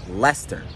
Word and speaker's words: Leicester: Should be a little bit better at this by now, because this is pronounced Leicester.